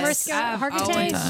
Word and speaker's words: Mariska Hargitay: Mariska Hargitay. Did I say it right? – Is that the Law and Order? – Hargitay. Yes. Mariska Hargitay.